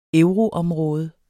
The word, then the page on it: euroområde: [ ˈœwʁo- ]; [ ˈεwʁo- ]